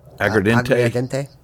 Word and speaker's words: aguardiente: Aguardiente? Aguardiente? – Aguardiente.